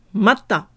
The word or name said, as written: mata